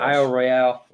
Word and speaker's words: Isle Royale: Isle Royale.